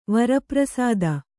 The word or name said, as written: ವರಪ್ರಸಾದ